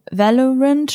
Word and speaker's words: Valorant: So Valorant, spricht man das so aus? – Ja.